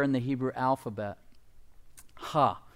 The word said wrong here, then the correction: He: or he, excuse me, H-E-H was how we would pronounce it.